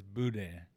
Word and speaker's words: boudin: No, the correct way, the word is spelled Boudin, and the correct way to pronounce it, if you're from New Orleans, and the way they pronounce it is boudin.